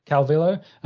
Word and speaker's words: Calvillo: How do you say her last name? – Calvillo.